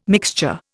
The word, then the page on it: mixture: Indian